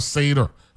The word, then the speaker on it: Seder: Even though it's spelled S-E-D-E-R, right? And when I saw it, I said, you know something, let me go look to see how you pronounce this one, because I don't want to make no mistake, right? So I went on Google and it says Seder, right?